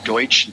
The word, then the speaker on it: Deutsch: Chris underscore Deutsch, D E U T S C H.